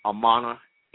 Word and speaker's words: Amaana: T-A-R-H-A-K-A, Amana, A-M-A-A-N-A, Elbey, E-L and then B-E-Y.